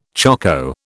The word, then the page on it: choko: British English